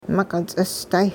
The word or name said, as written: miq'it-ts'isday